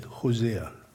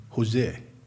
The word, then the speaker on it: Hosea: Yes, I did pronounce it Hosea. I didn't say Jose.